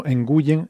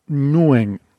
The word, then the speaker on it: Nguyen: Este apellido muy común vietnamita que solemos decir como Nguyen, en realidad se pronuncia algo así como Nguyen, es como lo dicen ellos,